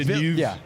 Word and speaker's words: Villeneuve: No, I really thought Villeneuve, doesn't he pronounce it Villeneuve? Anyway. – Villeneuve.